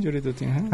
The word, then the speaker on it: Jury Duty: Jury Duty, Ahí está.